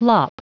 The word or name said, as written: lop